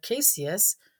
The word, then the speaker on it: caseous: The pronunciation of our English word caseous is easily comparable to the beginning pronunciation of queso also.